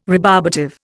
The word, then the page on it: rebarbative: Indian